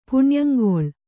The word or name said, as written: ಪುಣ್ಯಂಗೊಳ್